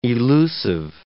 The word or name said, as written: elusive